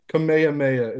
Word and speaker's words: Kamehameha: King Kamehameha is how you say it in Hawaiian.